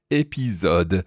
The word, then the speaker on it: épisode: So it's as if it's spelled E-P-I-Z-O or Z-O-D-E. Épisode.